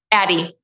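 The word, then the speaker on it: Addyi: Addy. – Mm-hmm. Addy.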